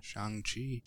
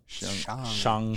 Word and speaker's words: Shang-Chi: Shang-Chi. – Shang-Chi.